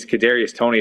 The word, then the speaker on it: Kadarius Toney: It might be, I believe it's Kadarius Toney is how you pronounce it.